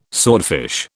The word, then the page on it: swordfish: British English